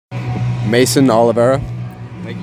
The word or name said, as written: Mason Oliveira